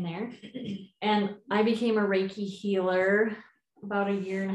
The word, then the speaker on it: Reiki: And the first thing I want to say about Reiki is, well, it looks like it should be pronounced Reiki because it's R-E-I-K-I. – Or Reiki. – It's actually Reiki. And it's a Japanese word. Rei and ki is life force energy.